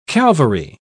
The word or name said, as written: calvary